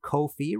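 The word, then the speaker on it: Ko-fi: If you liked this show and want to support future episodes, please head over to Kofi, rhymes with no fee.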